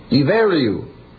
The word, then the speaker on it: Ibheriu: One of the ancient names of Ireland is I-B-H-E-R-I-U. Pronounced I-B-E-R-I-U. Iveriu. But that's the way it's spelled.